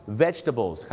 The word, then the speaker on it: vegetables: vegetable vegetable ไม่ใช่ vegetable นะครับ vegetables ครับผม vegetables เขาจะไม่ออกเสียงตัว jet ครับดังนั้นนะครับอย่าลืมครับ vegetables 3 พยางค์แต่ผ